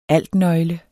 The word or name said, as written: altnøgle